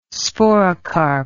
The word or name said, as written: sporocarp